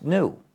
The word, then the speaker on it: GNU: Because according to the dictionary, it's pronounced new. The G is silent.